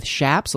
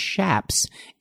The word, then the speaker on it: chaps: They are leather leggings, not to be confused with chaps. A lot of people call them chaps, but they're actually pronounced shaps.